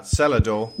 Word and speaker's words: Celador: Celador? – No, it's Celador, with a hard C, from the Celtic, darling.